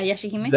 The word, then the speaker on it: Yashahime: Yeah, Yashahime.